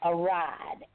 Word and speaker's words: Aryde: So if anybody reads it, here's the pronunciation. It's called Aride, A-R-Y-D-E. Because you hear people say, I'll be over there when I get a ride.